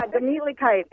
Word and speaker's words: Gemütlichkeit: But it's the first time I learned how to say Gemütlichkeit. Am I saying it right? – Yeah, Gemütlichkeit.